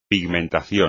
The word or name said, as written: pigmentación